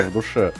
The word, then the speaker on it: в душе: Я даже в душе иногда о нем думаю. Ой, неправильное ударение, ну в душе, в душе.